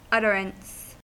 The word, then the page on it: utterance: American English